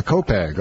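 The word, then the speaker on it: Copiague: And we have Mario from Copag. Is that how you pronounce? – Yes. – Copag. Okay, Copag.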